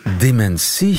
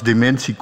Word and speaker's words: dementie: En in het Latijn was het dementia, dus lag die klemtoon wel degelijk op men. Eh, en dat is, wordt inderdaad, ik zal niet zeggen door alle Nederlanders, maar sommige Nederlanders verschuiven dat en zeggen dementie.